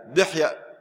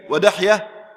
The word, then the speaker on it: دحية: وكان جبريل عليه السلام ينزل في صورة دحية الكلبي، دحية تنطق بفتح الدال وكسرها، دحية الكلبي ودحية الكلبي، هكذا ضبطه جماعة من أهل العلم.